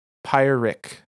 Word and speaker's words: Pyrrhic: Pyrrhic. So you were correct about the pronunciation.